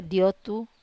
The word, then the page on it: otu: wamesa